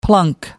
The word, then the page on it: plunk: American English